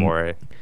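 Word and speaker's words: D'Amore: And Demore. Yeah. It's Demore, dude. – I thought you pronounced it. I was like, isn't it Demore? – I don't know.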